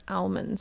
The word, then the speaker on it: almond: So, first things first, is it almonds or is it almonds? That's my first question. Do you pronounce the L? Yeah, for sure. Almond, yeah. A lot of people don't, so controversy, let us know what you think.